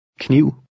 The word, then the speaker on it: kniv: The first two letters are pronounced separately. – Kniv.